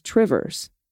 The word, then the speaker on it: Two Rivers: How would you pronounce Two Rivers? Well, the local pronunciation is Trivers.